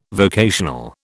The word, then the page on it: vocational: British English